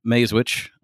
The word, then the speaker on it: Masewich: Owner of Goodson Clothing and Supply, and I have Kate Masewich.